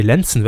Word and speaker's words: Lenzen: als Medienthema der Woche Sat 1 Detektivserie Lensen. Ne, Lenzen wird das dann wohl ausgesprochen, ne? – Ja, ja.